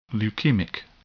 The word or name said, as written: leukemic